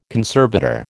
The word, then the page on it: conservator: American English